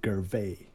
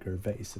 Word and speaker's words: Gervais: Med han Ricky eh, Gervais, eller Gervais eller man, hur man Gervais. uttalar det. – Gervais.